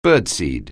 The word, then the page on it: birdseed: anglais